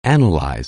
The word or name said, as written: analyze